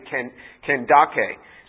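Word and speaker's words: Kandake: Candace is like Pharaoh, it's a particular name, and we pronounce it Candace, but it's really phonetically Kendake. So in some translations you will see it spelled out that way, and that's probably more accurate.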